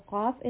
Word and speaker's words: qaf: So qaf is pronounced when the root of the tongue touches the soft part of the upper palate.